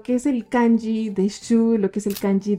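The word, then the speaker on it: shu (酒): Y el segundo kanji es el kanji que se pronuncia shu, pero también se pronuncia como sake y es bebida alcohólica, básicamente.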